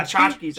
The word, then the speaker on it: tchotchkes: I pronounced that. I saw the word coming up and I was like, what the fuck? And then I was like, tchotchkes, that's what it is.